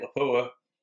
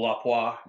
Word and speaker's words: Lapua: We did some 338 Lapua, or Lapua, depending on how you pronounce or how you like to pronounce it.